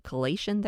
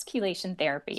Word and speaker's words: chelation: Is it chelation therapy? Am I saying that right? – Yes, chelation therapy.